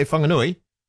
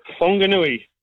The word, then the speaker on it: Whanganui: Did you say Whanganui? – That's right, good morning. – Morning. – Whanganui with an H. Whanganui. Whanganui. – Whanganui, yeah, I'm never 100% sure how to say it, so I move it around a little bit.